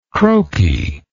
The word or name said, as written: croaky